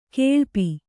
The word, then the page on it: ಗುಳುಪು: guḷupu